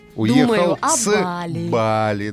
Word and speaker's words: Бали: Или Бали, как говорят местные. – Угу. Вот мы сейчас много обсуждали, куда же все-таки правильно ставить ударение. И в русском языке все-таки на первый слог.